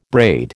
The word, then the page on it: braid: American English